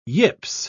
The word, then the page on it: yips: British English